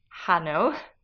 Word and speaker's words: Hanno: Hanno. – Spelt H-A-N-N-O. So as we know, the H is always silent in Italian. So it's spelt like Hanno, but it's actually pronounced Hanno.